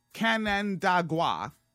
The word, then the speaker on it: Canandaigua: So, Brian Kolb, Republican of, okay, let's pronounce this, Canandaigua, bam, nailed it, and said in a statement that he will seek professional help while continuing to serve his ninth term in the assembly.